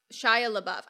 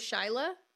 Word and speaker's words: Shia LaBeouf: I can't say his name because for the longest time I thought it was Shyla. And then I thought it was Shia LaBeouf. And so first name Shyla, last name Buff.